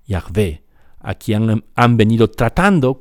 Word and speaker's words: Yahvé: Y los especialistas en hebreo, en antiguo hebreo y en, la Biblia, en el Antiguo Testamento, nos dicen la pronunciación más probable de YHWH es Yahvé.